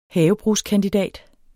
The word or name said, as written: HAVEBRUGSKANDIDAT